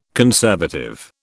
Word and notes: conservative: British English

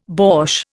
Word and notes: borsch: Indian

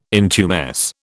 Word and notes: intumesce: British English

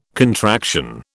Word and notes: contraction: British English

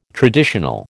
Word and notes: traditional: American English

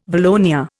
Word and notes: valonia: Indian

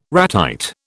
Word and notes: ratite: British English

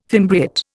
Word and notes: fimbriate: Indian

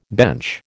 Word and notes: bench: American English

